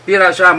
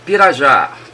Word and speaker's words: Pirajá: Pirajá, my name is over. – And can you say your name one more time, por favor, please? – Okay, Pirajá. Pirajá, Papa India, Radio Alpha Juliet Alpha, Pirajá, over.